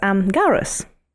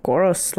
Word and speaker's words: Gaura: as Garas. – Elaborate on that, because I can't remember who are they. Garas? – If you or Goros, like people pronounce it differently, if you Google it, G-A-U-R-A.